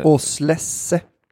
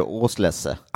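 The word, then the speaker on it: Auslese: Oslesse. – Ja, Osless. – Osless. – Då, Tyskland från Mosel. – Ja, är det tyskt, då blir det Auslesse. – Auslesse. En Auslesse då kan man dra i sig.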